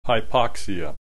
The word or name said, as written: hypoxia